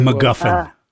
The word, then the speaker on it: MacGuffin: We did the McGriffin or whatever. – MacGuffin. – MacGuffin. – Right. And you almost didn't say it correctly once.